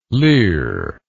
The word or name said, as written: lir